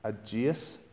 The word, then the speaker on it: Aegeas: He too was cured by Andrew and became a Christian, and therefore, how do you say this? A-E-G-E-A-S. Aegeus.